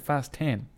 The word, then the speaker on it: Fast X: F9, the Fast Saga, and then Fast X. But I believe you're supposed to pronounce it Fast 10.